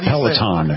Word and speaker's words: Peloton: Peloton.